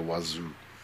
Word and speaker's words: Oizo: It's actually pronounced Monsieur Wazoo.